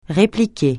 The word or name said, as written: répliquer